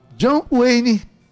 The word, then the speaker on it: John Wayne: John Wayne.